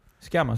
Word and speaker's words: Sciamma: Es una película eh, dirigida por Celine Sciamma. No sé si se pronuncia así. Schiama, sí.